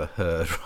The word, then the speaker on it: Heard: Amber Heard and the fact that Amber Heard sadly rhymes with turd.